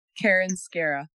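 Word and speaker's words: Karen Skarra: It's Karen Scara. – Karen Scara. Okay, awesome. Mm-hmm. And that's spelled K-A-R-E-N. Last name is S-K-A-R-R-A. Is that correct? – Excellent.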